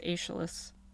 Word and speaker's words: Aeschylus: The first time they ever appear are in the Iliad, but they're mostly associated with Aeschylus.